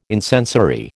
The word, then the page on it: incensory: American English